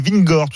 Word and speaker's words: Vingegaard: D'ailleurs, on ne sait pas trop comment ça se prononce. Thibaut Texier disait Vingegaard tout à l'heure. – Oui, bah c'est plutôt la bonne version.